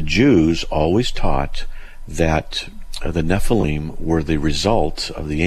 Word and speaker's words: Nephilim: I think I'm saying that correctly. – Nephilim, yeah.